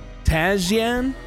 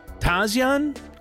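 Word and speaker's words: Tasjan: Aaron Lee Tazian. I think it's Tazian. It's spelled Tazjan, but it's Tazian, I believe.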